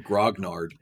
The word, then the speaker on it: grognard: I'm actually mispronouncing it. I do it every time. Okay. It's actually grognard. – Yeah, that's even funnier. – It means, it means that, like the old guard. – Okay. – Soldier for original imperial guard that was created by Napoleon is a grognard. I always say it wrong, though. I say grongard.